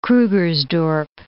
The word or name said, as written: Krugersdorp